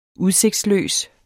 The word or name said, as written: udsigtsløs